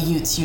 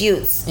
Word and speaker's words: Ute: Oh, the Utes, like the Utes. University. – Utes. – Yeah, the Ute. Yeah. – It's not okay. UT, it's Utes.